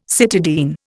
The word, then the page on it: cytidine: Indian